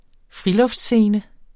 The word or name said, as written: friluftsscene